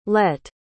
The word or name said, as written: lette